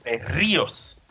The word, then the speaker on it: Berríos: Eso, Berríos, acento en la I, por supuesto.